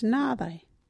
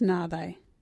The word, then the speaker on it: nhaaathayi: local Wadjuri word is nādai. What is nādai?